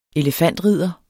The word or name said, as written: elefantridder